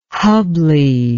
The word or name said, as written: hubbly